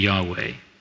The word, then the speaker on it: Yahweh: The second, all capital L-O-R-D, indicates that it is a translation of that Hebrew name of God. It is the YHWH tetragrammaton that is unpronounceable, but according to most scholars, the pronunciation is probably Yahweh.